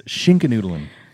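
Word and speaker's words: Schinkennudeln: So in Germany, Schnickelnudeln. – It's Schinkenudeln.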